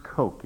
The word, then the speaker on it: Koch: Kurt Koch, it's pronounced like Coke, but it's German K-O-C-H.